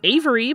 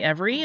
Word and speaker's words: Every: You'll sometimes hear his name pronounced Avery, but it's generally Every, and that's what we're going with.